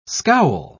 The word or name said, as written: scowl